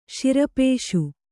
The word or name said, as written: ಶಿರಪೇಷು